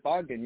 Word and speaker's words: Bogdan: Is it Bogdan? No, man, you said it perfectly. Yeah, it's Bogdan.